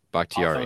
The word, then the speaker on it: Bakhtiari: I don't know if I'm saying his name right, David Bakhtiari. Bakhtiari. Yes.